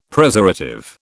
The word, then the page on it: Preservative: British English